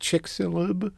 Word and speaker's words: Chicxulub: Speaking of chicks, I want to ask you about an event called, I think it's pronounced Chicxulub.